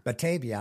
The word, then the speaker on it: Batavia: And out in your way in Ohio, Reverend Michael Foster of Batavia. I guess I'm saying that right. – Batavia.